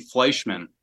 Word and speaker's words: Fleischman: Oh, it's actually Fleischman. Okay. Kind of like the vodka, but it's spelled a little different. It's one N, not two. So it's the most Wisconsin last name I've ever heard in my entire life.